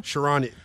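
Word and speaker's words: Charania: According to Shams, how do you say his name? Sharanya? – Sharanya. There you go, Chris.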